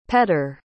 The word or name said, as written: petter